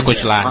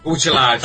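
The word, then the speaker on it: Cotillard: Marion é, Cutilar, exatamente. – Cutilar. Não, é, o... A gente tem que pronunciar o nome certo aqui, viu? Porque tem gente aí reclamando.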